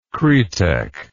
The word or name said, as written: kretek